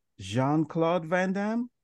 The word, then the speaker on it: Jean-Claude Van Damme: Is it Jean-Claude Van Damme? – Very you got nice. It. Yeah. – Okay. I thought it was Jean. It's spelled Jean. I'm just a hopeless American like that. But what do I know? Jean it is.